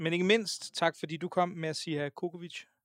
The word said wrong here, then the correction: Djokovic: Og nej, udtalte jeg det igen forkert. Ret mig lige. – Djokovic.